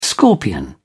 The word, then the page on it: scorpion: British English